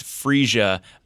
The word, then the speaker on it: Phreesia: This is Phreesia.